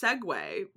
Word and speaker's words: segue: Well, the one girl didn't know that it was pronounced Segway because, like, okay, so there's like Segway that you ride on, which is spelled Segway.